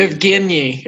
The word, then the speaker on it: Evgeny: Canadiens are plus 140 with the Penguins, and Sidney Crosby and Evgeny Malkin. I don't know why I can't pronounce his name right now. Probably Evgeny. It's a soft G.